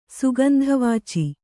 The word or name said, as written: ಸುಗಂಧವಾಚಿ